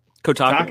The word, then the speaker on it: Kotaku: Kotaku.